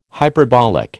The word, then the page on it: hyperbolic: American English